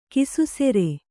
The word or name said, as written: ಕಿಸುಸೆರೆ